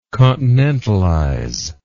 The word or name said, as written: continentalize